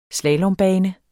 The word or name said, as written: slalombane